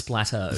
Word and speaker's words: Desplat: yes, Alexandra Desplat. That's probably not how you say it. It's probably Desplo or something like that. – No, it's Splatto.